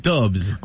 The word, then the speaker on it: DVBBS: Là, j'ai vu DVBBS, mais ça se prononce comment, hein? – Dubs.